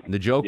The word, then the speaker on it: Njoku: You know, I like Njoku. Is that how you pronounce it? – Sure. – David Njoku. – Njoku, yeah.